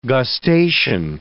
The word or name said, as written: gustation